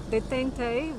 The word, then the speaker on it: détente: Protocol, not allowing the Middle East conflict to complicate détente with the USSR.